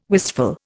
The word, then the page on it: wistful: Indian